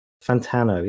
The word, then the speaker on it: Fantano: Anthony Fantoni. Is that how you pronounce his name? – Fantano, yeah.